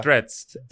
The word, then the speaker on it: Threads: Threads. – Threads. – Threads. – Voy a threadear. – Voy a threadear. – Voy a threadear. – No, chicas, eso yo lo vi en Threads.